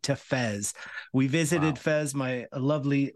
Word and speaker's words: Fez: Am I saying that right, Fez? – Yeah, you've got it right.